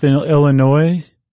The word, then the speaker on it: Illinois: Followed by Monmouth, Illinois. I forgot the S is silent.